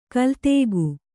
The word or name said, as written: ಕಲಾದಕ